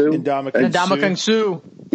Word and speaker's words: Ndamukong Suh: Ndamukong Suh.